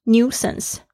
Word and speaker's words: nuisance: 这边要特别注意一下这个 nuisance 的拼法， n-u-i-s-a-n-c-e，它的这个 u-i 是发 u 的声音，或者是 u 都可以，英式发音会发成 nuisance，美式发音是 nuisance。